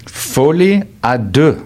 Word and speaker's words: folie à deux: Folie à deux.